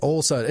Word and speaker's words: Olson: It is old son. It is son. Think son. I'm a son of old. Not S-E-N.